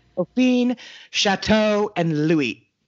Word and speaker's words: Louis: The future Louis XIII came to Versailles for his first hunting trip in 1607. – It's Louis.